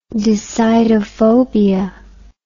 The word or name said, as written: decidophobia